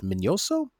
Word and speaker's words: Minoso: I believe I think it's pronounced Minoso, uh, but uh, I think there's a tilde in there somewhere.